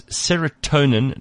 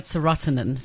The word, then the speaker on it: serotonin: No, she was calling it serotonin. So she was putting the emphasis on the wrong syllable of serotonin.